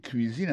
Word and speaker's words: cuisine: Ou dans le cuisine là-dedans, c'est comme ça on dit cuisine, on prononce cuisine en anglais. C-U-I-S-I-N-E. C'est cuisine.